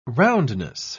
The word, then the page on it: roundness: British English